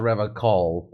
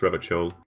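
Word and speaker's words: Revachol: And he also refers to Reva Shawl as Reva Call, which one of the loading tips will actually tell you is the dumb way of pronouncing it. – It's like a racist dog whistle type thing. Like, racists call it Reva Cole, everyone else says Reva Chole.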